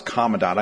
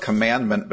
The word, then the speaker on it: commandant: It's pronounced commandant. I know Canadians pronounce things a little bit different. Actually, I have been introduced as the 24th commandment before.